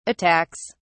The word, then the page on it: attacks: British English